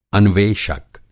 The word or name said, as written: अन्वेषक